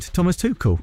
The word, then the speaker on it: Thomas Tuchel: Just quickly, thoughts on Thomas Tuchel. Did I say that right? – You did. Thomas Tuchel.